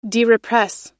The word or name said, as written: derepress